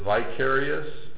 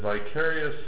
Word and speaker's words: Vicarius: And on this crown, it had an inscription, and it said Vicarius. It's spelled V-I-C-A-R-I-U-S.